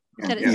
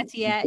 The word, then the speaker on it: Etienne: But I think they go running back, and that is where I have Travis, how do you say, Etienne? Etienne. So beautifully. Yeah. Etienne, yes. Okay.